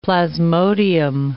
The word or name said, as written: plasmodium